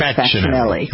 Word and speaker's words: Facinelli: He pronounces it the correct way, which is Facinelli. – Yes. – Right. The C-I in Italian is pronounced like a C-H.